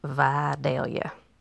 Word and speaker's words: Vidalia: So number one is Vidalia. Where the onions come from, it's Vidalia. Okay.